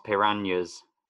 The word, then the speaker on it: piranhas: Oh yeah, Helga is fed to Blofeld's piranhas, to pronounce them correctly, in You Only Live Twice.